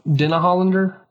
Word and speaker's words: Denhollander: rachel denhollander